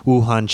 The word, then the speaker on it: Wuhan: Man from Washington State returned home after a trip to Wuhan, China.